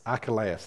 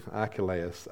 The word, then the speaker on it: Archelaus: But he was warned again, don't stay here, because yes, Herod's gone, but his son, or the king, I can't pronounce that name, does anyone know what that name is? Just Archelaus, thank you, Archelaus was king, and the angel said, not a good guy, you need to go down to Nazareth.